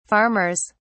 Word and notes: farmers: British English

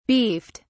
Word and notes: beefed: British English

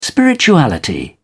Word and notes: spirituality: British English

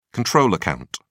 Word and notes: control account: British English

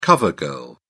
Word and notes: cover girl: British English

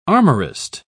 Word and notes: armorist: British English

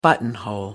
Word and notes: buttonhole: American English

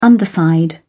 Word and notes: underside: British English

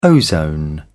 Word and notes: ozone: British English

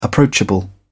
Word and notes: approachable: British English

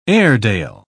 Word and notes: airedale: British English

